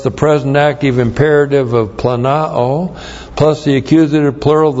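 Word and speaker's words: planao (πλανάω): Planao, P-L-A-N-A long O, planao.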